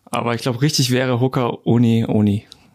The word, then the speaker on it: Hoka One One: Ich bin jetzt mal auf einem Account und da habe ich hier, wie spricht man die eigentlich aus? Ho Hoka? Hoka? Hoka Uni Uni. Mhm. – Wie? Hoka? Uni Uni müsste, äh, ist richtig.